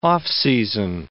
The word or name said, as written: off-season